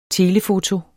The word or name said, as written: telefoto